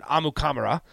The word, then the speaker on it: Amukamara: or if you ask Prince himself, he pronounces it Amukamara. Is how he pronounces his last name.